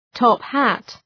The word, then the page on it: top hat: ,tɒp’hæt